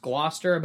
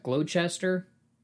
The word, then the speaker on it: Gloucester: Okay, like, you know that word, it's pronounced Gloucester, but it looks like Gloucester. C-E-S-T-E-R.